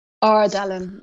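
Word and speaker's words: Għar Dalam: I think, is it Gahar Dalam? Is that how you pronounce it? – Ardalam, yeah.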